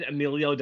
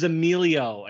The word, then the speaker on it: Emilio: And I said Emilio Delgado's name wrong like a million times. His name is Emilio, and I was saying it Emil the entire time.